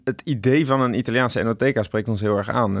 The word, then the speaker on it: NOTK: Maar er waren al meerdere enoteca's bij de KVK geregistreerd. En toen bij het beetje sparren over het ontwerp van het logo ontstond het idee van NOTK. Op zijn Nederlands spreek je uit als Enoteca.